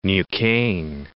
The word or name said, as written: Neuquen